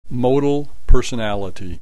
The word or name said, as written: modal personality